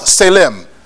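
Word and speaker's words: tselem: It is spelled T-S-E-L-E-M. T-S-E-L-E-M. It is pronounced selem.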